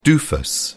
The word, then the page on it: doofus: British English